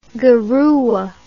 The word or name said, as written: garua